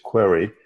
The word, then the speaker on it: Querai: The name of the company is Querai. It's spelled Q-U-E-R-A-I, like you're running a query with artificial intelligence built into it.